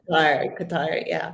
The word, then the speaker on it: Qatar: if I'm pronouncing it correct, because I've heard Qatar, and then I've heard Qatar after. I was like, no, I'm pretty sure it's Qatar. – Qatar.